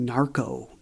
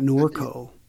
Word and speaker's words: Norco: I've got to say, when I first saw the title of your book, I kept thinking Narco, not Norco. Norco, of course, is the place where this happened. But if people are searching for it, it's spelled N-O-R-C-O.